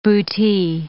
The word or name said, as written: bootee